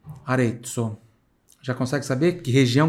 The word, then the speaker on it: Arezzo: Arezzo, onde tem Z aqui, tem som de TS.